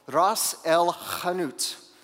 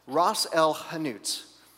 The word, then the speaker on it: Ras el hanout: It's called Ras El Hanout, okay? And you have to say it like that every time you say it, okay? Or it doesn't count. Ras El Hanout, okay?